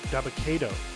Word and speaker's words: Dabba-Kato: Daba Keto. Daba, Dabba-Kato. Yeah, that's how you pronounce it.